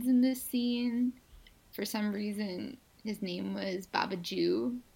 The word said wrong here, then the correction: Babajide: Yeah, Baba Jeed. B-A-B-A-J-I-D-E.